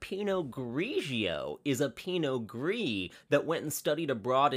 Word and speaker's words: Pinot Gris: Pinot Gris. Not Grigio. – Gris. – Not Noir. – Just Gris. – Pinot Gris. Which is spelled G-R-I-S, like Grizz. Like Gris.